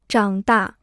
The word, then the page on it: 长大: zhǎng dà